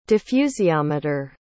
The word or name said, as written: diffusiometer